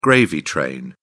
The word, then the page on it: gravy train: British English